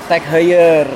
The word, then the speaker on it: Tag Heuer: Tag Heuer.